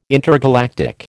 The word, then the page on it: intergalactic: American English